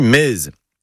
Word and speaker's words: mez: Ça se dit mez. M-E-Z.